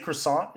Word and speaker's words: croissant: Well, when I say croissant, is that I being pronounced? – It's croissant. – So the T is silent. Croissant.